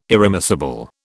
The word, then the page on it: irremissible: British English